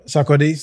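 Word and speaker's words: Sarkodie: Sardoki, for sure. – Sakodi, from Ghana. – I always say his name wrong. – Yeah, Sakodi, yeah, from Ghana. – He's always calling him Sardoki. Sakodi, for sure, from Ghana.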